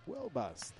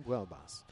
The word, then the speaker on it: Buelbas: And I found out how to pronounce his last name. Buelbas. There you go. Brian Buelbas.